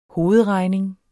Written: hovedregning

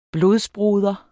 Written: blodbroder

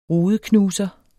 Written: rudeknuser

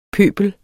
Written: pøbel

